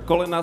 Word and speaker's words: kolena: Uh, kolena skláňam rád. How do you say it? Kolena skláňam rád. Wait, slow, slow for me. Kolona? – kolena. – Kolena.